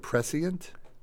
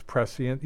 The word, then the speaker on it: prescient: No, it's prescient it's prescient. – Oh, well, maybe if you're a pointy-headed intellectual, it's prescient. Yeah, that's the British pronunciation.